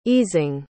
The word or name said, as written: easing